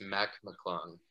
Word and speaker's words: McClung: Jacob told me how to pronounce last name. I already forgot. it's Mac McClung.